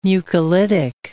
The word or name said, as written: mucolytic